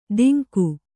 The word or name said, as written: ಧಿಂಗು